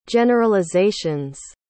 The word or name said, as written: generalizations